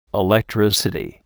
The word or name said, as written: electricity